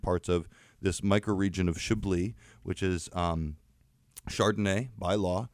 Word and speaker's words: Chablis: The Jug of Chablis, which is very often a sweet white wine, which is hilarious once we taste these wines today. Chablis is how it's properly pronounced.